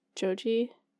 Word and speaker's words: Joji: He went to go see Joji.